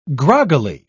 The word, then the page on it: groggily: British English